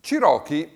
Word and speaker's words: Cherokee: Cherokee, con l'accento sulla prima, ha però una caratteristica interessante che deve aver attratto l'attenzione dei jazzisti.